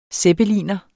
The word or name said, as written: zeppeliner